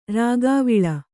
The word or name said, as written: ರಾಗಾವಿಲ